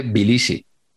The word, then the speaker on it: Tbilisi: La, T primera no la, no la pronuncian, porque luego habló del equipo que había, que había para nosotros en Tbilisi. Sí. Y, él dice Bilisi.